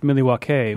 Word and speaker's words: Milwaukee: Actually, it's pronounced Milwaukee, which is Algonquin for uh-huh. – the good land.